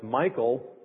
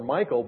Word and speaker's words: Michal: To me, it just looks like Michal. Now, I have an older brother named Michal, so it's hard to call her Michal. But nevertheless, I believe that's how it's pronounced.